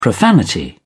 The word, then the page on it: profanity: British English